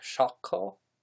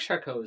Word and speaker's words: Charcot: and I think they pronounced his name more like Charcot, something like that. But think Charcot is pretty okay for American. – Pretty, yeah. Anglicized. In order to not sound pretentious, I'm just going to continue to say Charcot.